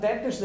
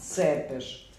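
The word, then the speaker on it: Țepeș: I ne kaže se Vlad Tepeš, nego Vlad Cepeš.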